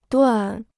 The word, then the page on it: 段: duàn